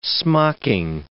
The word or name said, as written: smocking